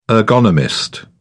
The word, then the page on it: ergonomist: British English